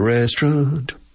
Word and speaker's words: restaurant: When did you go to the restaurant? When did you go? When did you go to the restaurant? We went to the restaurant. Restaurant. Restaurant. Lo decimos casi como dos sílabas.